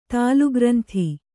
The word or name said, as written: ತಾಲುಗ್ರಂಥಿ